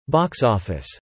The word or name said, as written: box-office